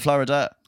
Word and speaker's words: Flo Rida: His name is spelled Florida.